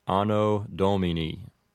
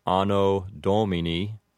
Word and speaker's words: Anno Domini: I suppose that a more correct pronunciation would have the long vowels like this, Anno Domini. But the way you might commonly hear this, pronounced with an Americanized pronunciation, might be Anno Domini.